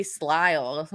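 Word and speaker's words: Slye: I know they addressed that by kicking, but please don't kick him, by signing kicker Joey Slile. Am I saying that right? – I think so.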